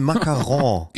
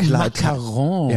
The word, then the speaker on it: Macaron: Die heißen Macaron. Diese Ach so, Macaron. – Ja, ich habe es falsch gesagt.